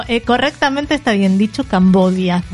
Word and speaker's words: Cambodia: En realidad, si vos lo encontrás en los mapas y hablas con la gente del lugar, todo el tiempo dicen Cambodia.